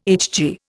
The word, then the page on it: HG: Indian